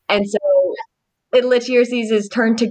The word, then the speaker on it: Lityerses: I think it's Lityerses is how you pronounce it.